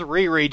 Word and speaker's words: Riri: Yes, Danger, I know her name is pronounced Riri. Just go with my joke, you punk.